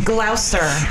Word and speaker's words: Gloucester: Death of the Fox Brewing Company out of, how do you say the town? Because I always fuck it up. – Gloucester.